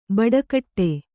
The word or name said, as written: ಬಟ್ಟೆಗೆಡು